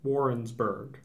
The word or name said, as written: Warrensburg